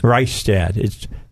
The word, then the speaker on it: Reistad: Is it Reistad? – Reistad. – That's a German name. – It's a Norwegian name. – Norwegian. – And it's pronounced, okay. – One side of the family from Wisconsin calls it Reistad. The other side of the family from Minnesota calls it Reistad. Same spelling.